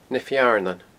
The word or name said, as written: Na Fearnan